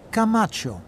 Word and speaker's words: Camacho: Camacho. – Can you spell that, please? – Yes, of course. C-A-M-A-C-H-O.